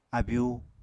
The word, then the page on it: abyu: Apatani